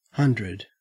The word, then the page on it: hundred: Australian English